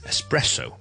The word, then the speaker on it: espresso: 其中一个最常听到嘅咖啡室英文嘅错误咧，就系咧一个好特浓嘅咖啡，我哋一个 espresso, E S P R E S S O。 Espresso，佢系 espresso，重音系第二个音字咧，而第一个 syllable 记住 es 咧，系唔需要加个 k 音喺度嘅，即系我哋譬如 expert 啦，或者 express 啦，或者 experience 啦，呢个 ex 咧，我哋会读个 k 音嘅，ex，咁但系呢个好浓嘅咖啡咧，我哋咧系一个 espresso。